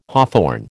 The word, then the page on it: hawthorn: American English